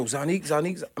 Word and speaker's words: Zonnique: Yo, like, niggas like, yo, Zonnique, am I saying it right? – Yeah.